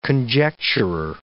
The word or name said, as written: conjecturer